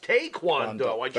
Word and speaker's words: Taekwondo: It's not Taekwondo. It's properly pronounced Taekwondo.